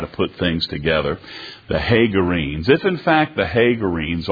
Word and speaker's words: Hagarenes: Hagarenes. It's a long A. Hagarenes. Take a look here in 1 Chronicles 5.